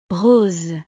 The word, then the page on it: rose: frances